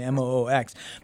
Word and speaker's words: MOOX: I'm not sure if I'm pronouncing it correctly, M O O X. But this is more of the commercial side of the conversation. Let's check this out. – So we're heading into the uh, the MOOX. I just learned is the pronunciation here.